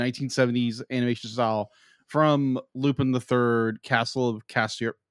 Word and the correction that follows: Cagliostro: How do you pronounce it? – Well, Castle Cagliostro is what you're trying to say, yes.